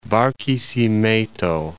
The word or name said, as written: Barquisimeto